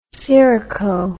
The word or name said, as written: syroco